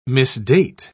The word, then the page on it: misdate: British English